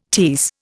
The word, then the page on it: tierce: Indian